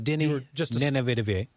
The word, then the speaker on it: Denis Villeneuve: Denis Villeneuve. I think I said that right. – That's very close. – The director, Denis Villeneuve.